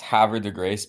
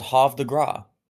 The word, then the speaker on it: Havre de Grace: It always killed me about that town that it's pronounced Havre de Grace because, like, the one time that I thought I was being like a cultured person, I was like, oh no, it's Havre de Grace.